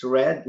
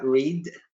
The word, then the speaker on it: Red: It's Red, not Reed. Red Andal here from Liftow.